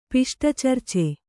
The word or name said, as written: ಪಿಷ್ಟಚರ್ಚೆ